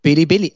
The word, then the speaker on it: Bilibili: I don't think you're pronouncing it right, dude. – Bilibili. It's the Bili.